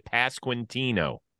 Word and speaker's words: Pasquantino: We're good with Pasquantino. – Yep, that's perfect.